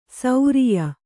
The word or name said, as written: ಸೌರಿಯ